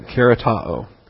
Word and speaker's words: charitoo: All right, karatao. C-H-A-R-I-T-O-O. It's got the O-O ending with the short O and the long O, the omicron and the omega.